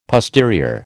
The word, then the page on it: posterior: American English